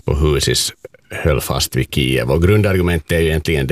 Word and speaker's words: Kiev: gick över till Kiev, om det nu är ungefär sådär som det uttalas. – Ungefär så, Kiev.